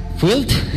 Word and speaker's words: Fueled: que esto se pronunciará Fuelit o Fueled. Field. Field. Field. Ahora ya estamos.